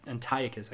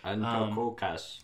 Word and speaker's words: Antiochus: Antiochus, I keep pronouncing that wrong. – Antiochus.